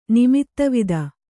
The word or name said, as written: ನಿಮಿತ್ತವಿದ